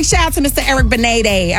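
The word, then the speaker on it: Benét: But he corrected everybody recently on his Instagram by saying it is Benet. It is Eric Benet. So just in case you didn't know, that's the correct way to pronounce his last name.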